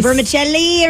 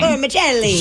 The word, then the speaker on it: vermicelli: Vermicelli or something like that? – That's perfect. – Yeah. – You pronounced it amazing. – Vermicelli.